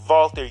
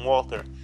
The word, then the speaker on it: Walter: Yes, it's pronounced Walter, you fucking idiots who call him Walter.